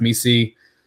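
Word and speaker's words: Missi: But, you know, Jacoby Walter, Yves, I think his last name is pronounced Misi.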